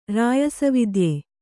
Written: ರಾಯಸವಿದ್ಯೆ

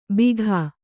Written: ಬೀಗ

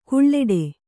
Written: ಘೀಳಿಡು

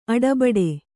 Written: ಅಟ್ಟಬೆಟ್ಟ

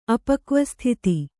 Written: ಅಪಕ್ವಸ್ಥಿತಿ

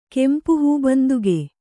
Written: ಕೆಂಪುಹೂಬಂದುಗೆ

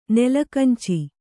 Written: ನೆಲಕಂಚಿ

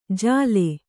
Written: ಝಲೆ

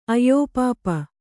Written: ಅಯ್ಯೋಪಾಪ

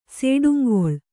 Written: ಸೇಡುಂಗೊಳ್

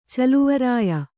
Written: ಚೆಲ್ವರಾಯ

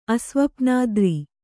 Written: ಅಸ್ವಪ್ನಾದ್ರಿ

